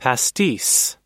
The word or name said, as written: pastis